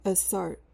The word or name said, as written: assart